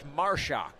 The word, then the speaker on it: Marusak: And if you saw how Marshak spells his last name, you would not think it is Marshak. 2-1 pitch. Foul straight back to the screen. 2-2 the count. The way Marshak spells his name, it would be Marusak. M-A-R-U-S-A-K. But pronounced Marshak.